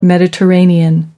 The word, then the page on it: mediterranean: American English